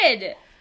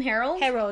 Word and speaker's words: Herod: His name is Herod.